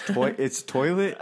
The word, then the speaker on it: Toylit: It's Toilet. They're like, Toilet? It's like, yeah, but it's spelled T-O-Y-L-I-T.